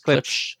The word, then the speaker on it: Klipsch: Whatever, how do you pronounce that? Klipsch? – Klipsch. – You got it.